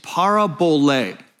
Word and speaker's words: parabole: Parabole with the accent on the last syllable. That's why I put it up there on the number and the parentheses. So parabole, capital L, capital E, that indicates the accent.